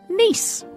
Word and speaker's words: Nice: Now, thankfully, Arnott's replied, and they said, "Nice biscuits," I'm reading the spelling, "were named after the city in the south of France and pronounced the same as the city. They've been part of the Arnott's range since 1922. So that means, in fact, the Nice biscuit is pronounced Nice.